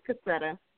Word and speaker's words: Cassietta: Well, first of all, my name is Cassietta. It's pronounced Cassietta.